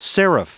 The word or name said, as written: seraph